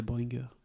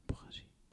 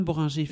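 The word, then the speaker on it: Bohringer: Mais je croyais que c'était Boringer. – Mais non, Boringer. Donc c'est Roman Boringer.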